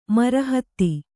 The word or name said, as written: ಮರಹತ